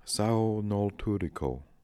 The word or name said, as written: saonoltooriko